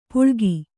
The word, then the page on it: ಪುಳ್ಗಿ: puḷgi